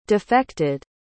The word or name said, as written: defected